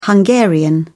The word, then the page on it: hungarian: British English